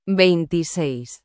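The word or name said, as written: veintiséis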